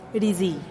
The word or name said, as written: rizii